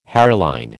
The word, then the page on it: hairline: American English